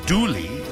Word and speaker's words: Dula: When you look at the guy's name, it says Dula, but the actual pronunciation is Dooley.